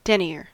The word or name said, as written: denier